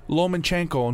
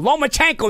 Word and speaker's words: Lomachenko: But he's calling me about Lomachenko and who Lomachenko is. Lomachenko. Another one they call him Lomachenko. Lomachenko. There's no N.